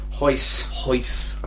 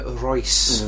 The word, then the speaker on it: Royce: How do you say his first name? – Hoyce. Apparently. – What would an idiot call him? – Well, I called him, you say idiot, but I called him Royce.